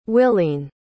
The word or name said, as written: willene